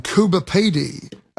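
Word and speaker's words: Coober Pedy: And I did take the Indian Pacific to places like Kalgoorlie, a mining town, and Kuba Pedi.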